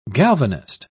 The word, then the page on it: galvanist: British English